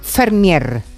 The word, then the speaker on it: Vermeer: que Vermeer en neerlandés se pronuncia Fermier. – Anda. – ¿Vale? O sea que lo de Vermeer es, es Fermier.